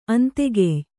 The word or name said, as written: ಅಂತೆಗೆಯ್